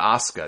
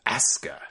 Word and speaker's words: Asuka: It's not Asuka, it's Asuka. Just like Triple H was Triple H.